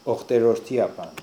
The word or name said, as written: Okhterordiapan